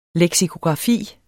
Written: leksikografi

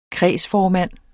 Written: kredsformand

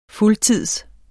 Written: fuldtids

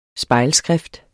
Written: spejlskrift

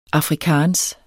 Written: afrikaans

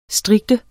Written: strikte

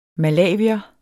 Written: malawier

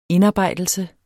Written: indarbejdelse

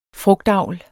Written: FRUGTAVL

